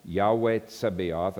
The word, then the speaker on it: Yahweh Tzebaoth: Those of you who are purists and like the Hebrew pronunciation, it's Yahweh Tzebaoth. Yahweh Tzebaoth, and that occurs 245 times.